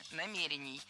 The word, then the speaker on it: намерений: Сказали, что у нас нет никаких намерений предыдущего руководства. – Намерения, это неверно. Правильно, намерений. Ударение в этом слове ставим на второй слог и не меняем это ударение при изменении слова. Намерению.